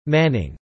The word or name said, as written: manning